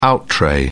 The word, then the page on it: out-tray: British English